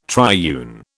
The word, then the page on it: triune: British English